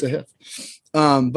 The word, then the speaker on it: Sahith: Like last week, I thought fucking Sahith Thagala. Apparently, that's how you say it now. It's Sahith. He came out and said it's Sahith.